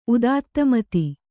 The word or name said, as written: ಉದಾತ್ತಮತಿ